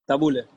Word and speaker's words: tabule: Y e por fin, eh, para terminarmos este show, ¿qué que é que diz o tabulé? No sé si estou a pronunciar bem. – Tabule, tabule.